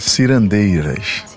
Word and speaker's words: cirandeiras: Il y a un morceau qui rend hommage à ce qu'on appelle les cirandelas, si je prononce convenablement. Qu'est-ce que c'est les cirandelas ? – As cirandeiras. – Cirandeiras.